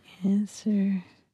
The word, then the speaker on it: answer: Or answer. – W. – W yeah. and Neil.